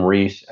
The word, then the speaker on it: Ries: It's Jim Ries, and its last name is R-I-E-S. Pronounce Ries like Reese's peanut butter cups, but not spelled that way.